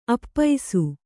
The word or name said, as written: ಆಪೋಸ